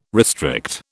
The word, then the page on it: restrict: British English